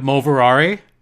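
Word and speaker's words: Moverare: Jacob Moverari. That's how you pronounce his name.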